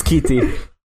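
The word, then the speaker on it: Schiti: Don't say Shitty. – Oh yeah, Valeri Shitty. – It's Skeety. – Skeety. – Yeah. – I always want to pronounce the H. – Yeah, it's the opposite as in mistake. – German. Like, in German, the S-C-H is the sh... But in Italian, the S-C-I would be she.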